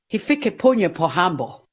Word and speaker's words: Hifikepunye Pohamba: Hifikepunye Pohamba.